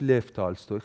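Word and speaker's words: لف تالستوی (Лев Толстой): اسم کتاب آقای صحت، کتاب اعتراف نوشته لئو تالستوی، یا درسترش لف تالستوی.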